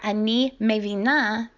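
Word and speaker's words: ani mevina (אני מבינה): This is ani mevin if you are a man, or ani mevina if you're a female. Ani mevin, ani mevina. Pay attention to the stress on the last syllable.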